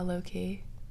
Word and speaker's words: Loki: Am I saying that right? Loki? – Yeah, Loki. – Loki. – Loki. Loki. – Loki. – Loki. I feel like I'm saying high low key.